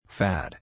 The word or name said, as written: fad